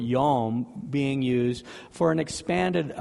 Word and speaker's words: yom: And in his sovereign naming, he names the light or the illumination day, which is the Hebrew word yom, Y-O-M, would look like how it's spelled if it was on a piece of paper.